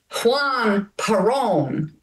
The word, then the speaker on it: Juan Perón: In Evita, now I hope I pronounce this right, correct me, Juan Perón. – Well done.